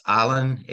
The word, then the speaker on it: Ahlen: I was born in, it's pronounced Allen, A-H-L-E-N in West Germany.